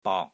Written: BOK3

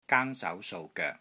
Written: GAANG1 SAU2 SOU3 GOEK3

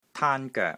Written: TAAN1 GOEK3

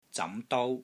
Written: ZAM2 DOU1

